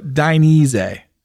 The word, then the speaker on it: Dainese: But a commonly mispronounced name, especially in the U.S. market. So Dainese. – So say, we're going to go out to dinner and dine. Dine. – No, more like dye. Like you're dyeing the leather.